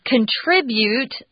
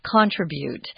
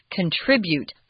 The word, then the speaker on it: contribute: Something you add, I want to remind everybody that when you pronounce the verb form, contribute, that the zhongyin or the heavy accents on the second syllable, I hear a lot of contribute in Taiwan, which is incorrect. Contribute.